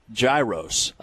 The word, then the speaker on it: gyros: And then for lunch earlier today, I had the, it's not a hard G, it's a soft G, I've been told, the gyros.